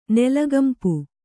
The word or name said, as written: ನೆಲಗಂಪು